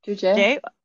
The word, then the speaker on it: Joojeh: And then the name they give it after that is Juje, I think. Yeah. – Juje. – J-O-O-J-E-H.